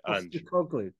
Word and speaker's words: Postecoglou: We're not talking about how do you say his name? Podjikoglu? – Postecoglou, right? – Postecoglou.